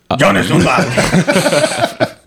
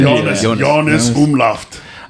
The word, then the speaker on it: Jonas Umlauft: Okay, he's German, so say it like a German. – Jonas Umlauft. – It's a soft J, I want to say. Jonas Umlauft.